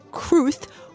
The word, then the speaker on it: Cruz: For the record, what is the correct pronunciation of your name? – Cruz. – With a theta. – Cruz, or Cruth, won some of the best reviews of her career for her role in this film.